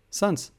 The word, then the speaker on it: Sans: Sans? – Meinst du den, den Genau, ich, ich, konnte das nie aussprechen. Wie heißt der Sans? Sans. Ah ja, genau, äh, Sans, genau, richtig, genau.